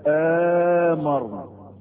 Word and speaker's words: آمرنا: في قراءة ثالثة ان الهمزة اللي على ألف أمرنا ما تبقاش همزة، وإنما تبقى همزة المد، فتنطق ازاي؟ آمرنا، تنطق ايه؟ آمرنا.